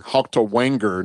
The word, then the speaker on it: Haltewanger: And left guard, Robert Haltewagner. I think I said that right. – Haltewanger, now that I see the N is before the G, excuse me.